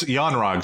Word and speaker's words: Yon-Rogg: Uh, Yorog, right? – Okay. – I think, am so, I saying that right? – Yeah. Sure. Why not? so, yeah. – We'll just, yeah, it's Yon-Rogg.